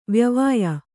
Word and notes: ವಾಯವ್ಯ: vāyavya

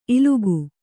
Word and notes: ಇಲಿಕೆ: kannada

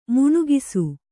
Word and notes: ಮಿಣುಕಿಸು: miṇukisu

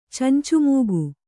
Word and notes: ಚಂಚುಮೂಗು: kannada

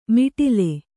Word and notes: ಮಿಟಿಲೆ: kannada; miṭile

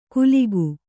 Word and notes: ಕುಲಿಗು: kuligu; Kannada

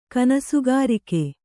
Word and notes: ಕನಸುಗಾರಿಕೆ: Kannada

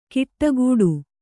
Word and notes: ಕಿಟ್ಟಗೂಡು: kiṭṭagūḍu

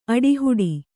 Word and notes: ಆಟಹೂಡು: āṭahūḍu

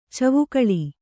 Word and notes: ಚಿವುಕಳಿ: Kannada

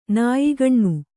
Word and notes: ನಾಯಿಗಣ್ಣು: kannada

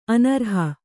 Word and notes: ಅನರ್ಹೆ: anarhe

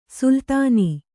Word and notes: ಸುಲ್ತಾನ್: sultān

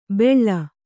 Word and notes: ಬೆಳ್ಳ: beḷḷa

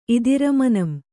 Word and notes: ಇದಿರಮನಂ: Kannada

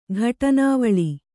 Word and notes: ಘಟನಾವಳಿ: kannada

